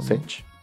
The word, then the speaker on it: Sech: Este chico Sech, o no sé cómo se pronuncia, no sé. – No, Sech.